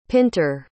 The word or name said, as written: pinter